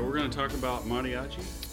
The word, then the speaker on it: mariachi: How do you say it? – You mariachi? Say you are a little bit like you a soft T. – Roll it a little. Yeah. Mariachi.